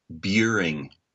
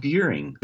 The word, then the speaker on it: Buehring: It's Buring. It rhymes with curing. I'm memorizing it now. – So try it like this. So curing, Buring. Buring. There you go.